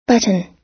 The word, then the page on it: button: American English